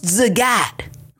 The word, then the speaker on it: Zagat: He was a very happy, well-fed man who was often heard over saying to people, it's pronounced Zagat, like the cat. It's Zagat, like the cat in a hat.